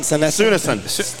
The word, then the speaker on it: Sunesson: Sunesson.